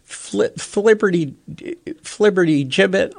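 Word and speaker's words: flibbertigibbet: Oh, it's a soft G. Flibberty gibbet or flibberty gibbet T.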